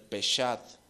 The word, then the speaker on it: Peshat: El Peshat. Se escribe P-E-S-H-A-T.